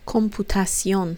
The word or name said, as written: computacion